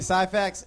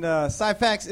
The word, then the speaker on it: Syphax: It's Randy Syphax, and Syphax is a unique last name. So I was able to go, Syphax, what is that? You know, and it's spelled S-Y-P-H-A-X.